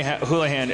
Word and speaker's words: Houlihan: Mary Houlihan.